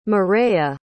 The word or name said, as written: mireya